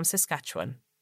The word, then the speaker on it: Saskatchewan: And finally, I hope that one day Sean will learn how to properly pronounce the name of my province. Camille from Saskatchewan. – Well done. She got it right.